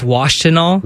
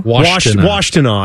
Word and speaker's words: Washtenaw: I believe it's Washtenaw. – Washtenaw.